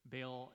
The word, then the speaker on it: Baal: Baal is actually pronounced Baal, but for the sake of ease, let's just call him Baal.